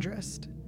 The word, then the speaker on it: Andrist: Andrist. – Hold on. Wait till these annoying motorcycles are gone. Why do they have to be so loud? Cool. – Because they have little weenies. – Andy Andrist.